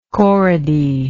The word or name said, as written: corody